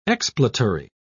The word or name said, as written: expletory